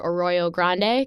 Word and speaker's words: Arroyo Grande: Arroyo Grande, California. Am, mm-hmm. I, am I pronouncing that correctly? Perfect. Uh, great.